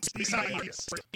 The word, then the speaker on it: Isaias: A lot of people, you know, prayers to everybody involved, but it is Isaias. – Is that how you say it, though? – You do.